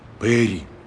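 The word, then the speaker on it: пери: А Пэри произносится Пэри с ударением на «э».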